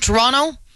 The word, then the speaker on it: Toronto: Now, if you're from Toronto, like I am, you know that you don't say that second T. And in the movie, they punch that T like it's the hardest syllable in the world, okay? Toronto is how they said it throughout the whole movie, and it actually kind of took me out of the dialogue.